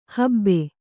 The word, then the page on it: ಹಬ್ಬ: habba